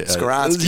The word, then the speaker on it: Skoronski: I, Skoronski.